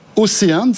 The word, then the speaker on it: Océane: Vous êtes le PDG de Océane, c'est comme ça qu'on prononce. – C'est exactement ça.